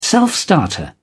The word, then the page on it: self-starter: British English